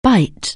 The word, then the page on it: bite: anglais